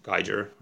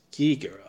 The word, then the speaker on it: Giger: is it pronounced Geiger? Or? – I think it's Giger.